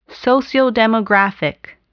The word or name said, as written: sociodemographic